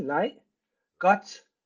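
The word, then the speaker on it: leg godt: Light, L-E-G, light, gut, G-O-D-T. – Gut, light gut. – Light gut, yeah.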